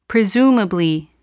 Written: presumably

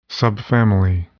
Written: subfamily

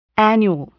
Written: annual

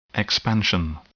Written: expansion

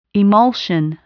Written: emulsion

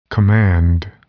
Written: command

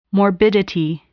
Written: morbidity

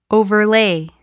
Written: overlay

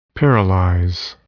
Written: paralyze

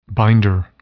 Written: binder

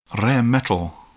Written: rare metal

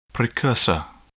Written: precursor

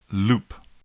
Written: loop

